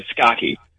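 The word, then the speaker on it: Scacchi: And Scotchy in Italian, it means chess. You know, so I don't know if that's how you pronounce it the way my grandfather would, but we say it as Scotchy.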